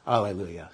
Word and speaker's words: Alleluia: When it's spelled with an H, that's a nod to the Hebrew spelling and pronunciation, Hallelujah. And what's with A, that's a wink and a nod to the Greek spelling and pronunciation, Alleluia.